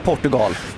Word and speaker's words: Portugal: Man kan absolut säga Portugal. Jag vill tydligt markera det att det är helt okej.